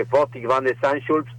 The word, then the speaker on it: Botik van de Zandschulp: Espero lo pronunciado bien porque es complicado el apellido de este muchacho, en una gran remontada, hay que decirlo, del mejor tenista argentino que tenemos hoy, por lo menos en el ranking ATP. Y para hablar de esto y mucho más del mundo de la raqueta, estamos con el número uno en el mundo del tenis. Buenas tardes. Este lado, ¿cómo estás? – Hola. Hoy perfectamente pronunciado.